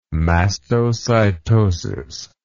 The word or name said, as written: mastocytosis